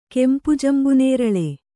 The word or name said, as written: ಕೆಂಪುಜಂಬುನೇರಳೆ